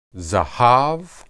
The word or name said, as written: zahab